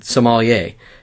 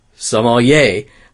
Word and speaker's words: sommelier: For what it's worth, he has a friend who is a professional sommelier, and he pronounces the word sommelier. I think it's actually an issue of we were putting the accent on the wrong syllable. – Exactly. – So we were saying sommelier instead of sommelier.